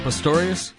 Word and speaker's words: Pistorius: Is it Pistorius? – Yes.